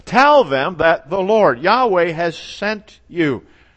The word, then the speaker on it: Yahweh: Sometimes it's pronounced Jehovah. Sometimes I always call it Yahweh, which is probably a more direct translation.